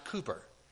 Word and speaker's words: Cowper: You spell it C-O-W-P-E-R, but it's pronounced Cooper.